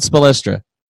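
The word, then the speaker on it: Spoelstra: Zach in the background, have you ever heard anybody pronounce it Spolstra? – How are you supposed to pronounce it? – Spolstra. – Spolstra. – Oh, he's not spe, he's spo.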